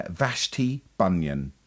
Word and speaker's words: Vashti Bunyan: Vashti Bunyan.